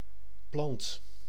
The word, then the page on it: plante: France; /plɑ̃t/